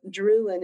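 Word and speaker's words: Drewien: I before, and it's pronounced Drew in as opposed to Drew out. So it's Drew in, but it's got too many vowels.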